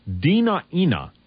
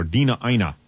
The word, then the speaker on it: Dena'ina: There's also some critical new information in this particular story, and that is that the Dena'ina Indians, now that's spelled capital D-E-N-A apostrophe capital I-N-A, or Dena'ina tribe, in the Kenai Peninsula in Alaska, were also subjected to this very same experimental hepatitis B vaccination program.